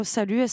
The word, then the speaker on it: SLT: Oh, salut, SLT, ça marche, quoi. Les deux marchent.